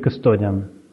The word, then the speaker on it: кастодиан: Э, это э, администратор и вторая, вот как правильно говорить, кастодиан, кастодиан или... – А, мы обычно говорим кастодиан.